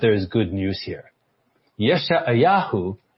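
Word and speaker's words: Yeshayahu (ישעיהו): And I know some of you pronounce differently than I do, but the Hebrew is Yeshayahu. Yeshayahu.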